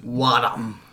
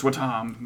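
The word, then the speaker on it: Wattam: Wadam. The much anticipated. – Which, of course, now is confirmed to be pronounced Watam.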